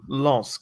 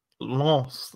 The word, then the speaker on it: Lens: But it's like the nasal Lens, like Lens. Not lens. Lens.